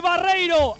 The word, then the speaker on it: Barreiro: Manu Barreiro, eso, perdón. Sí. Manu Barreiro desde la derecha. El balón en zona de tres cuartos. El centro esta vez sí que fue muy bueno de Alex López. Y Barreiro, ahora sí lo digo bien, con un remate absolutamente inapelable.